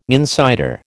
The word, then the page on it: insider: American English